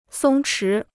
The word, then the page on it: 松弛: sōng chí